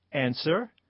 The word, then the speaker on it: answer: Answer. – W. – W and Neil.